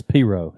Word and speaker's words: pirogue: Making pirogue. Or how do you say it? P-I-R-O-G-U-E? – It's Piro, isn't it? – Piro? – Yeah. Okay, sorry.